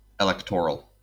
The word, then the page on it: electoral: /ɪˈlɛk.tɚ.əl/; Colorado